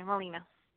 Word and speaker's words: Melina: Oh, sorry, Melina. – How do you spell it? – M-E-L-I-N-A, yes.